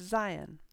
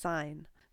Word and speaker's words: seien: And the command form is neither of these, it's seien. For instance, seien Sie vorsichtig, be careful. Although this often does get pronounced as sein.